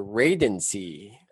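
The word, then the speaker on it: Radancy: TMP changes their name to Radency. – I know. What is that? It rhymes with agency.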